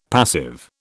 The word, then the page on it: passive: British English